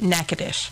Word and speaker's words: Natchitoches: Because I'm just saying, I have to work with branches in Natchitoches, Louisiana, and that's not spelled anywhere near what it looks what? – Like. – What? Natchitoches? So how do you spell that? – Natchitoches. N-A-T. – No. What? – I-C-H.